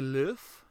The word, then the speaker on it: Louvre: And yeah, while this movie started with her in the Louvre, is that how you pronounce it? – Yeah, that's right.